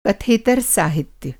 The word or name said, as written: कथेतर साहित्य